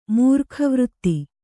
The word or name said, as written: ಮೂರ್ಖವೃತ್ತಿ